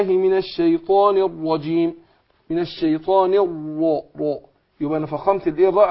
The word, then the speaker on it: الرجيم: والجيم ما انطقهاش معطشة كده بالشكل ده. هي معطشة. لكن كتير يعني من الأخطاء الشائعة في نطق الجيم إن كثير من الناس بينطقها إيه؟ زي الشين. يقول إيه؟ أعوذ بالله من الشيطان الرجيم. دخل على الإيه؟ على الشين. ما ينفعش.